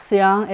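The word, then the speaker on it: Seon: Following liberation from Japan in 1945, the Seong monks, Seong is Zen, as pronounced in Korean, and we pronounce it in, I'm a Chinese person, so in Mandarin we pronounce it as Chan, not the same character, which is how we recognize things coming from East Asia.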